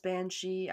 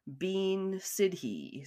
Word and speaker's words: bean sidhe: if you didn't know it was pronounced banshee, I would probably pronounce it bean sidhe.